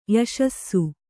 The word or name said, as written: ಯಶಸ್ಸು